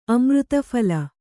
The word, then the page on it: ಅಮೃತಫಲ: amřtaphala